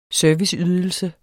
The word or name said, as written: SERVICEYDELSER